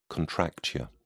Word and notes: contracture: British English

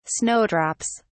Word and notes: snowdrops: English; British English

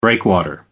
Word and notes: breakwater: American English

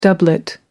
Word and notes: doublet: American English

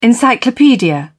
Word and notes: encyclopedia: British English

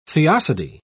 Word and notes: theodicy: British English

